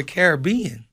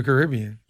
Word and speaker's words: Caribbean: If I'm talking about the movie, it's the Pirates of the Caribbean. – But if you're going on vacation, you're going to the Caribbean? – I'm going to the Caribbean.